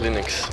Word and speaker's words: Linux: I pronounce Linux. – 142. – As Linux.